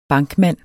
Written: bankmand

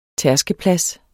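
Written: tærskeplads

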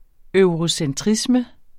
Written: eurocentrisme

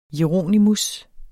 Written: jeronimus

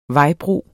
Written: vejbro

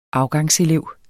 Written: afgangselev

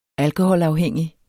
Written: alkoholafhængig

